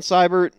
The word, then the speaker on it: Seibert: So, I'm going to go with Austin Seibert. I've said his name correctly twice now, and, it hasn't been the case all day.